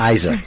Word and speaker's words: Eiser: Eiser, did I pronounce it correct? – Eiser, yes. – Eiser.